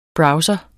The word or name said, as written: browser